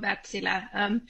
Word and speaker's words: Wärtsilä: Yes, that was good pronunciation of Wärtsilä. It's a company with almost 200 years of history from Finland. So back then we didn't really think about international pronunciation of the name. So you got it very well there.